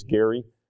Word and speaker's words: Gerry: I always think about Devin when I think about that because she wrote a PowerPoint presentation in government on Elbridge Gerry, or Gary. I guess there's a debate about how to pronounce it, and she's very particular. It's Gary.